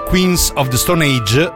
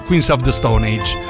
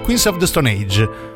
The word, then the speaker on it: Queens of the Stone Age: Insignificant Other, novità per i Queens of the Stone Age. E a tal proposito vi prego, si dice Queens of the Stone Age e non Queens of the Stone Age.